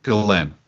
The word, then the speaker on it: Ghislaine: And is the proper pronunciation Ghislaine? – Or I think it's Ghislaine. – Ghislaine. Okay. Ghislaine Maxwell and Jeffrey Epstein.